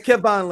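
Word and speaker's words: Kevan: It's Kevon Lannister, not Kerwin. It's Kevon for people that's listening for y'all.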